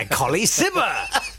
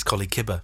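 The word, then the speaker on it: Colley Cibber: A new play by Colly Sibber. – Hooray! Yes. I'm pronouncing it wrong, by the way. I've been told off on Twitter. – Oh, how do you pronounce it? – Apparently it's Collie Kibber.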